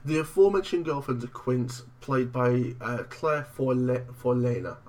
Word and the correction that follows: Forlani: I'm not sure how you pronounce it. – Forlani. – Forlani, thank you very much.